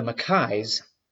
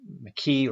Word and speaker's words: Mackay: So the Mackays were, you could pronounce that in America, it's usually Mackay or Mackie or Mackey.